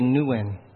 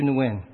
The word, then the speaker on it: Nguyen: And while most of the world pronounces it Nguyen, I actually say Nguyen. Sue Nguyen.